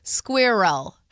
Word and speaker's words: squirrel: So it would be like squirrel. – How do you say it? – I say squirrel like it rhymes with girl.